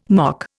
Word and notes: mock: Indian

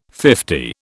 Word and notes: fifty: British English

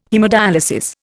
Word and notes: haemodyalisis: Indian

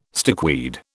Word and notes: stickweed: British English

